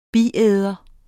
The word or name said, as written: biæder